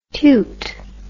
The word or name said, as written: tewt